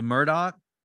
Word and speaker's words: Murdaugh: The Murdoch. The Murdogs. Murdochs? – Murdoch. The Murdochs. Is it spelled like, yeah. It, but Alex is Alec and Murdaugh is Murdoch. So that's just how South Carolina rolls.